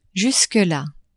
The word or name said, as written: jusque la